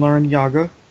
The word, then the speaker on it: Larrañaga: under the coach Jim Larrañaga, and yes, that is the correct way to pronounce his name, even though even he has said on record that it's been mispronounced ever since he was a kindergartner.